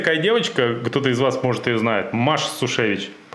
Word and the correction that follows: Сущевич: Правильно говорил Сущевич. Сущевич, да.